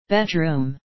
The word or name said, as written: bedroom